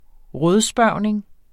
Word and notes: rådspørgning: ˈʁʌðˌsbɶɐ̯ˀwneŋ; ˈʁɔð-

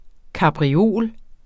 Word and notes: kapriol: kɑbʁiˈoˀl; kabʁi-

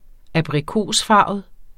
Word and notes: abrikosfarvet: -ˌfɑˀvəð; -ˌfɑːvəð

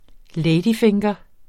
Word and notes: ladyfinger: ˈlεjdiˌfeŋgʌ

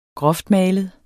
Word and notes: groftmalet: ˈgʁʌfdˌmæˀləð; -ˌmæːləð

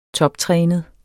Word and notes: toptrænet: [ -ˌtʁεːnəð ]; [ -ˌtʁεˀnəð ]

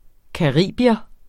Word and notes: caribier: kɑˈʁiˀbiʌ; ka-

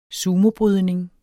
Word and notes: sumobrydning: [ ˈsuːmo- ]

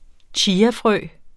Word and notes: chiafrø: ˈtjiːa-; ˈɕiːa-

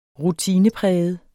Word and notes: rutinepræget: [ -ˌpʁεjəð ]; [ -ˌpʁεˀjəð ]

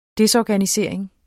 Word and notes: desorganisering: ˈdesˌɒːganiˌseɐ̯ˀeŋ; ˈdεs-

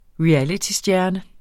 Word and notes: realitystjerne: ɹiˈaliti-

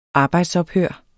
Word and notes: arbejdsophør: ˈɑːbɑjds-

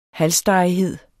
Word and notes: halsstarrighed: [ ˈhalˌsdɑˀiˌheðˀ ]; [ halˈsdɑˀiˌheðˀ ]

